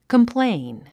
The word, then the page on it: complain: kəmpléin; コンプレイン